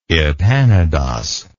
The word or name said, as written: epanodos